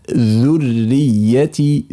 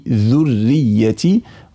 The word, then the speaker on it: ذريتي: ذريتي، ذريتي، هذه من المواضع التي ينبغي على القارئ أن ينتبه إليها أثناء النطق. والأخطاء فيها إما أن تفخم الذال تأثرا يعني بالراء ذو، أو أن تكرر الراء ذر، أو أن لا يخلص ضم الذال ذ، فهذه كلها أخطاء.